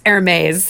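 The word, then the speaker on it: Hermès: They mispronounced Hermes. – I did notice that. They called it Hermes when talking Hermes. About the scarf at the scene. And I was like, it's Hermes.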